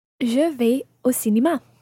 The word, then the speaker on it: vais: Now, je vais, vais is spelled V-A-I-S. So you could also say, je vais au cinéma, running your S into the O.